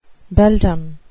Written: beldam

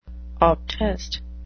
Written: obtest

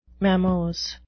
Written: mammose

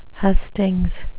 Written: hustings

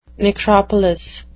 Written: necropolis

